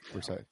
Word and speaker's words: Versailles: Versailles, I think is how you pronounce that. – I right? – think you're right. – Versailles.